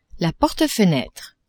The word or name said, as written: porte-fenetre